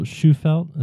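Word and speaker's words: Shufelt: And why you and Bill Schufelt, is that correct? How did I say his name right? – Correct.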